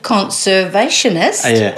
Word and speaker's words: conservationist: and also Kiwi conversationist. Is that how you say it? – Conservationist. – I mixed it up, didn't I? – You're allowed to.